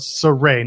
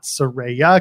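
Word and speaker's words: Sarray: We're talking about Saray, not Saraya, because there's some people can't pronounce it properly.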